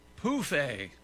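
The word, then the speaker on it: pouffe: Anyway, Europeans of a certain age use the French word pronounced pouffet for an ottoman, phonetically spelled P-O-O-F hyphen A.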